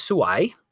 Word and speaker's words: suai: And the word is suai, which means beautiful. But if you say it wrong, it means terrible.